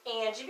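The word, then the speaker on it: Angye: Hi, my name is Anjanette White, better known as Angie,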